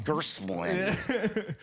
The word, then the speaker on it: Gerstmann: I was overpronouncing it, trying to be like, be nice and show him that he can say it. He's like overpronouncing it. – Yeah, it sounds so right that it's wrong. – It's Gerstmann.